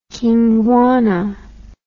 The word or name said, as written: Kingwana